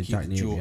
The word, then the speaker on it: Jack Napier: That's also your intro to Jack Napier. Am I saying that right? – I think so. That's how I would pronounce it, Jack Napier.